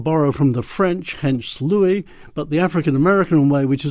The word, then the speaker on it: Louis: And I emphasize the Louis because in that song where he sings Hello, Dolly, he then goes on to say, this is Louis Dolly.